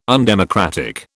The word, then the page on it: undemocratic: British English